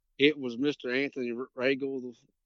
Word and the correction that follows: Rangel: I think I'm pronouncing his name right. Okay. Rangel, the gentleman that judged the cast, my first cast.